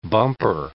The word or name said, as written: bumper